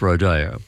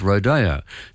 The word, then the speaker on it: rodeo: Now, in Spanish, it's definitely pronounced rodeo. And in America, in places which were settled by the Spanish, it is always pronounced rodeo. So the United States, California, Texas, New Mexico, all of those places that were settled by the Spanish, they say rodeo.